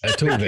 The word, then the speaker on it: Altuve: Altuve. Yeah, that's how you say it.